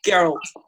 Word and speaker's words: Geralt: Is it Geralt? – Geralt. – I don't like that. – It's a hard G.